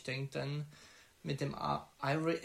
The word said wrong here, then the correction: Kyrie: wie spricht man aus? – Äh, Kyrie. Kyrie.